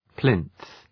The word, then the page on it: plinth: plınɵ